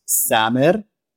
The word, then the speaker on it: Samer: My name is Samer. I'm Palestinian. So in Arabic, my name is pronounced Samer.